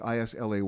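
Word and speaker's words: Islay: That's I S L A Y, but pronounced Isla.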